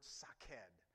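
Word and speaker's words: shaqed: In verse 11, the word for almond is saked. You have to pronounce it. It's the word saked.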